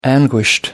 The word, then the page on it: anguished: British English